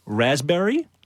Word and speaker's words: raspberry: K-N-E-E-L, Neil. – Raspberry, answer. – Silent letters. – Yes, silent letters. Raspberry is... – the silent S. – P. – Well, P. Sorry. – Silent P, right?